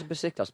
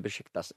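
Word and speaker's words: Beşiktaş: Where's Besiktas became Besiktas in the last year? Because people went, that's how you pronounce it.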